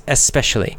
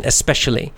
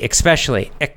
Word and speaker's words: especially: It's pronounced E-S, especially. That's the dictionary definition, you know, pronunciation, especially. There's no K or X sound in there. However, you will hear, especially Americans, probably most Americans pronounce it with the K sound, and they say especially, especially. Right? It sounds like there's a K or an X in there.